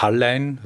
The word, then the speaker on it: Hallein: Also vielleicht Hallein, also muss man auf die Betonung aufpassen, aber ich habe letztens im Radio gehört, Hallein. Viele sagen Hallein, aber Hallein sagt man.